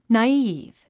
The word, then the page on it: naive: nɑːíːv; ナイーブ